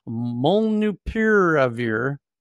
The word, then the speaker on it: molnupiravir: molnupiravir. Piravir. There it is. Molnupiravir. Molnupiravir. That's it. That's the Southern California official pronunciation, molnupiravir.